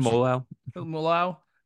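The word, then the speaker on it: Seumalo: How do you pronounce his name? – Semolau. – Yeah, I can't say that shit.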